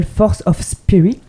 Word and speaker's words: Force of Spirit: Et justement, pour clôturer cette interview, comme il se doit, on va s'écouter tout de suite ton dernier single, Force of Spirit, si je le dis bien. – Oui, très très bien.